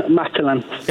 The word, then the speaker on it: Matalan: It was in rhymes with Patalan. – Rhymes and with Patalan. – Patalan. – Oh, yeah. Yeah. – What shop could that be? Okay. – Matalan.